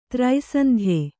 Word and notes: ತ್ರೈಸಂಧ್ಯೆ: trai sandhye

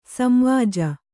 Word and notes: ಸಂವಾಜ: samvāja

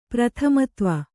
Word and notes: ಪ್ರಥಮತ್ವ: prathamatva

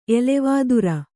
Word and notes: ಎಲೆವಾದುರ: elevādura

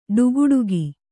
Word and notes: ಠಾಕುಟೀಕು: ṭhākuṭīku

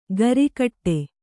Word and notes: ಗರಿಕಟ್ಟೆ: gari kaṭṭe; kannada

